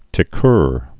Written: ticqueurs

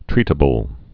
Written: treatable

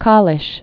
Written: Kalisz

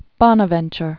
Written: Saint Bonaventure

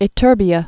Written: ytterbias

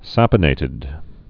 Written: saponated

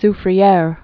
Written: La Soufriere

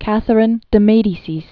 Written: Catherine de' Medici

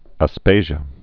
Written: Aspasia

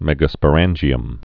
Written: megasporangium

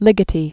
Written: Ligeti

